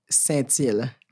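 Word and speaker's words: Sintyl: Okay, so it's Will Stencil, S-I-N-T, like Thomas, Y-L.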